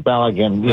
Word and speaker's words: Balogun: The how do you pronounce the name? Balogun? – Balogun. Yeah, right. Balogun.